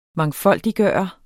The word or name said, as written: MANGFOLDIGGJORTE